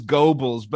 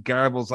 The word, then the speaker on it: Goebbels: And he's like, oh, it is Goebbels. But I just pronounce it Goebbels like an ah, that's not there.